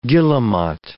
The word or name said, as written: guillemot